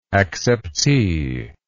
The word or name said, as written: acceptee